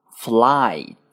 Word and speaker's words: flight: 第一个是 flight，双元音 i 以 r 开始，落音在一上，发音的时候呢，一定要饱满。 i flight flight，好，请跟我读 flight flight flight。